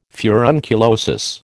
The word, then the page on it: furunculosis: American English